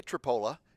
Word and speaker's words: Tripoli: And you can say Tripola, but it's Tripoli to me. It looks like Tripoli. It's spelled like Tripoli. It's Tripoli.